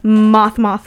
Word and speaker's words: Mothe: So, if you want to see that, go to Moth on Instagram, which is MOTHE, but it's pronounced moth, like the bug.